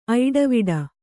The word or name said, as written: ಐಡವಿಡ